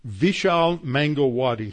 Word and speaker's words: Vishal Mangalwadi: Vishal Mangalwadi. His first name is spelled V as in Victor, V-I-S-H-A-L, and his last name is spelled M-A-N-G-A-L-W-A-D-I, Mangalwadi.